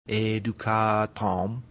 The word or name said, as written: educatum